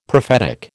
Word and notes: prophetic: American English

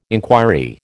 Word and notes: inquiry: American English

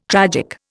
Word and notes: tragic: Indian

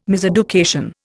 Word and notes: miseducation: Indian

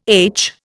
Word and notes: aitch: Indian

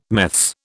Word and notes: meths: British English

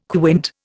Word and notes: quint: Indian

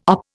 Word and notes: OP: Indian

